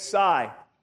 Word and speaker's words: Xay: picked up the financial support of Sai Savant Sodahook. And so Sai, you spell his name X-A-Y, you say it Sai.